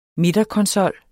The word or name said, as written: midterkonsol